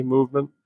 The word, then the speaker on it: MVMT: I don't know if you've heard, it's spelled MVMT, Movement.